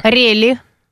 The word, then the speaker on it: рели: Ударение на «е». – Рели. – Да, рели.